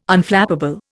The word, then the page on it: Unflappable: Indian